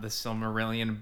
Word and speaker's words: Silmarillion: The Silmarillion.